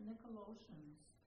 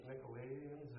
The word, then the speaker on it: Nicolaitans: Ooh, the Nicolaitans. – Yeah, you know, I tried finding references to them. The Nicolaitans or whoever.